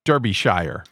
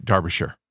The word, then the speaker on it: Derbyshire: And by the way, Derbyshire, which I've learned how to pronounce, is actually like spelled Derbyshire, but Derbyshire.